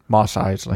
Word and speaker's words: Mos Eisley: Mos Eisley.